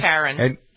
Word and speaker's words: Taron: Taron.